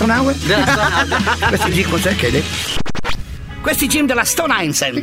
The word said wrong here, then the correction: Stone Island: Questi jeans della Stone Islands. – Ma perché scrivono come si legge? – Si chiama così, Stone Island.